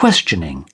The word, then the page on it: questioning: British English